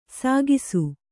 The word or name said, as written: ಸೇಕಿಸು